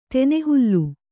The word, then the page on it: ತೆನೆಹುಲ್ಲು: tene hulflu